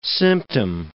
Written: symptom

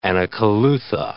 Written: anacolutha

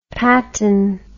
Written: patin

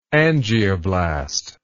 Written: angioblast

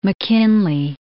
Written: McKinley

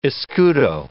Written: escudo

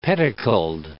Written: pedicled

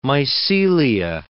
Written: mycelia

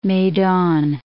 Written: Medan

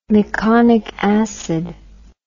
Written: meconic acid

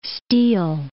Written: steal

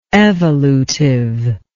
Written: evolutive

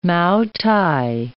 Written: mao-tai

